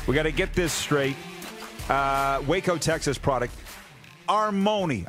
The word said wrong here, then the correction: Armoni: I'm saying that right. Armoni, correct? – It's pronounced Armani. – It is Armani.